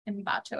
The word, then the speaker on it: Ambato: So in 1979, Pedro then traveled to Ecuador, where he settled in the mountain city of Ambato. Ambato, I did say that right.